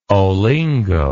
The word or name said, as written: olingo